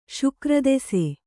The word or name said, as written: ಶುಕ್ರದೆಸೆ